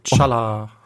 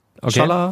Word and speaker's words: T'Challa: T'Challa. – Wie bitte? – Ja. T'Challa. – T'Challa.